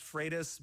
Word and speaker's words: Freitas: What's the right way to pronounce it? – Technically, it's pronounced Freitas, but my drill sergeant Freitas taught me to respond to anything close. – all right. Freitas is the right way to say it.